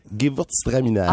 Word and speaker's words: Gewürztraminer: Gewürztraminer.